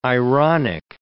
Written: ironic

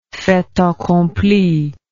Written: fait accompli

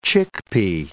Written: chickpea

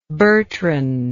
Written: Bertrand

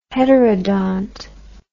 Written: heterodont

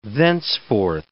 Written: thenceforth

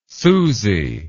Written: susy

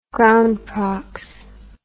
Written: groundprox

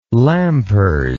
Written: lampers

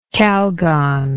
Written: Calgon

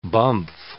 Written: bumf

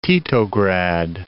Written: Titograd

